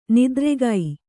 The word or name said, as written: ನಿದ್ರೆಗೈ